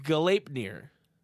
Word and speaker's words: Gleipnir: Keeping up with the Norse theme, the first transformation is Gleipnir. He included the pronunciation for me, so thank you, Brundax.